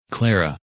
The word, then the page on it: Clara: English